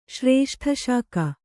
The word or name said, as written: ಶ್ರೇಷ್ಠಶಾಕ